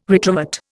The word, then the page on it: retrovert: Indian